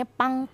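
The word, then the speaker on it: pang (빵): Nah tapi nol di sini nggak dibaca gong ataupun yong, tapi melainkan dibacanya adalah pang. Hmm. Ya, jadi nol nol dibacanya pang pang.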